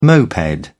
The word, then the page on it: moped: British English